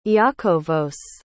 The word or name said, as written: iakovos